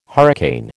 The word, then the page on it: hurricane: American English